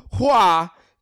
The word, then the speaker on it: Hua: Fa is the Cantonese pronunciation of Mulan's family name. Hua is the correct Mandarin pronunciation and means flower. Hua Ping, Mulan's fake name, means flower vase or vase.